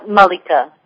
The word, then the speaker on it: Malika: Is it pronounced Malika or Malika? – Malika, yeah.